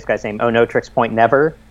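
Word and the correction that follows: Oneohtrix Point Never: One Tricks Point Never. – Mm-hmm. One Tricks Point Never.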